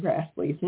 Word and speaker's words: Grassle: And looking at it, I really didn't think the odds were good that she pronounced it the same way. Turns out they did. So now I know how to say Karen Grassley's name correctly, which I am very glad about.